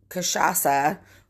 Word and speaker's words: cachaça: But cachaça, which when you look at it does not look like that's how you'd say it. It's spelled CACHACA.